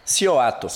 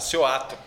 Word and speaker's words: Cioato: Cioato. Cioato.